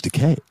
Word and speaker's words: Takei: I made that mistake for a long time before I met George Takei. And he was like, no, it's George Takei. – Okay. Excellent. It rhymes with okay.